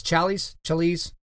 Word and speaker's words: Challies: So there's a guy online, actually, his name is Tim Challies, I think is how you say his last name, C-H-A-L-L-I-E-S, Challies, we'll say Challies, okay?